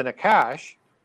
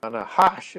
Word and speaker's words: Nahash: And the Nakash, or the Nahash, as it's more properly pronounced in Hebrew, the serpent, is the one who gets punished.